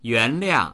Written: yuan2liang4

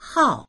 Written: hao4

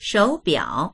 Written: shou3biao3

